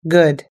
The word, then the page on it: good: us-english